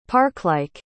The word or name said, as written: parklike